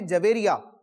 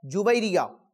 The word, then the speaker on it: جویریہ: بعض لوگ اس نام کو یوں پڑھتے ہیں جویریہ، یہ تلفظ درست نہیں ہے۔ علماء کرام نے اپنی کتابوں میں اس نام کا تلفظ یوں ذکر کیا ہے، جویریہ۔